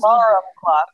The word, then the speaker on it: alarum: So the joke was that they were going to buy a bunch of alarm clocks, which, of course, in the book, they spell A-L-A-R-U-M, alarum. – I did not realize. That's how that narrator, the reader pronounced it in the audiobook, but I did not know that.